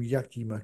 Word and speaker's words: Yakima: And I checked with the guy who sold me the bottle that I pronounced the name Yakima correctly. – yeah, it sounds, yeah, there's a area in Washington State, I believe, called Yakima, very similar, same spelling, I believe.